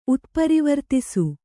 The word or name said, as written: ಉತ್ಪರಿವರ್ತಿಸು